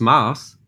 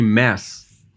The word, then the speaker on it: Maas: It's Maas. And most people name me Mass or Mass or something, you know? And most people even write me M-A-S-S, especially the US ones.